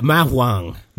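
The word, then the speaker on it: ma huang: I never could pronounce this right. Mao Hong. – Yeah. Mao Huang.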